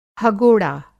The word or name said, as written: हगोड़ा